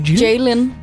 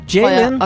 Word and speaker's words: Jlin: J-L-I-N? – Jalen. Jalen.